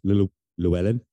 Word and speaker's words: Llewellyn: Is it Coach Llewellyn? Did I pronounce that right? – Mm-hmm, yes.